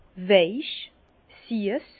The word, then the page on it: weich, siess: Bas-Rhin; Herrlisheim